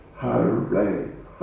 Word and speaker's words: Харе: Харе, Харе. No, it's not Аре. – Должно быть харе, не аре.